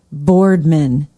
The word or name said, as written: boardman